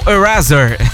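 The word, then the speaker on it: Erasure: e agora tocou O Lamour com Eraser. É, rapaz, o nome é assim mesmo, tá? Mas se escreve Erasuri, com S, tá? O S entre duas vogais tem som de Z até na língua inglesa.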